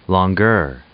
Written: longueur

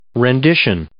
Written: rendition

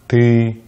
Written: ты

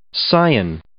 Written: scion

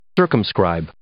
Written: circumscribe

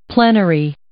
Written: plenary

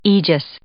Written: aegis

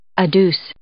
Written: adduce

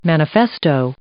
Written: manifesto

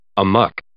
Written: amok

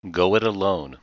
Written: go it alone

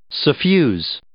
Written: suffuse

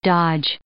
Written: dodge

